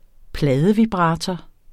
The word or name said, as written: pladevibrator